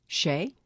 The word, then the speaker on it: Shea: Okay, how do you pronounce this? S H E A. What would you say that is? Shay. Shay. All right, here we go.